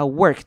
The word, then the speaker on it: work: 那同样注意到 work 这个字的 o r 会念成 er 的声音，不要念成 or，要念成 er。